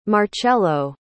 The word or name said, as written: marchello